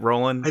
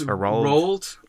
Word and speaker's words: Roald: Is it Roland? – I think it's Roald. It's like Ronald without the N, so I always go Roald.